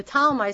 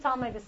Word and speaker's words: Ptolemy: The Ptolemy, spelled with a P, P-T-O-L-M-E-Y, got Egypt, North Africa.